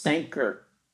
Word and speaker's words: Sanquhar: I don't know what a Q in Scottish sounds like. – Sanquahar. – Yeah. – Sanquhar. – But it was nice. It was very sleepy. Not necessarily a bad way, but there was just not a whole lot going on. Not a whole lot of shops open. – Sanker. – That's how it's pronounced. – Sanker. I looked it up. It said Sanker.